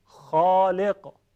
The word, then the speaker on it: خالق: حرف خا در زبان عربی چون صفت استعلاء داره درشت ادا میشه و تفخیم میشه. تفخیم یعنی درشتی صدا. ببینید خالق، تکرار کنید خالق،